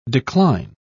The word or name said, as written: decline